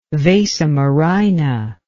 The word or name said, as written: vasa murrhina